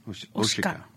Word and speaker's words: Oshika: Am I pronouncing that correct? – Oshika.